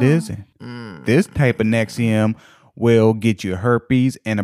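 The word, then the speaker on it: NXIVM: but no, it's actually pronounced Nexium.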